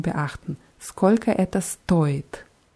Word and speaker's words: стоит: Und bei stoit ist es wichtig, die Betonung ganz deutlich auf O zu machen, weil stait heißt was anderes. Stait heißt steht.